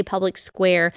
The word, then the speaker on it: Public SQ: It's spelled Public SQ, but you say Public Square